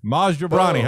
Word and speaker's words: Maz Jobrani: Well, I watched the thing that you described it as Maz Like Mazda, and then the name Joe, a bra like to hold up boobies, and then someone's knee.